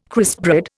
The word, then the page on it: crispbread: Indian